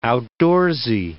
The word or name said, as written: outdoorsy